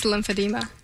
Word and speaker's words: lymphedema: I've never heard of lymphedema, which is good that you're here. Now we'll know what it is. Am I saying that correctly, first of all? – It's lymphedema. – Oh, there you go.